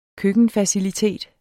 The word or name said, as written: køkkenfacilitet